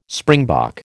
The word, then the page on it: springbok: American English